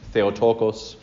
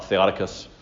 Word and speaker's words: Theotokos: Theotokos, not Theotokos.